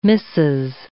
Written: missus

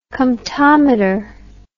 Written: comptometer

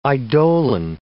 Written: eidolon

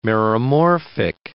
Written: meromorphic

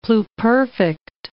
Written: pluperfect

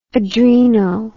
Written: adreno

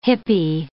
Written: hippie

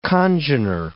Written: congener

